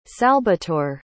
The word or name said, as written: salbatore